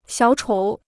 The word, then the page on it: 小丑: xiǎo chǒu